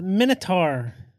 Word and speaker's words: Minotaur: So is it pronounced Minotaur or Minotaur? – No, it's Minotaur.